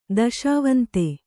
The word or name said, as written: ದೆಸೆವಂತ